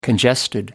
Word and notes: congested: American English

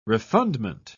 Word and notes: refundment: British English

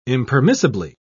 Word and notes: impermissibly: British English

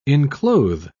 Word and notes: enclothe: British English

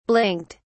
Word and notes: blinked: British English

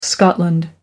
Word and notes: scotland: American English

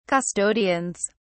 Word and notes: custodians: British English

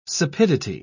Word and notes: sapidity: British English

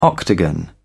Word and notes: octagon: British English

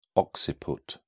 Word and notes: occiput: British English

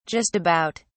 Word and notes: just about: British English

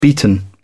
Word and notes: beaten: British English

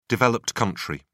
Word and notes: developed country: British English

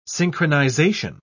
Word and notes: synchronisation: British English

